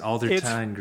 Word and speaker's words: Aldertine: You know, it, it's actually Aldertine.